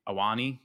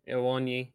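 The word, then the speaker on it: Awoniyi: And then the last one, the the dueling braces, uh, also came from a uh, Taiwo Awoni, right? Awani. Yeah, Awani.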